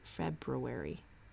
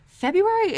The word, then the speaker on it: February: February. February is spelled so weirdly. February. Nobody says it right. February. Oh, I guess you do. There's an R in there, right?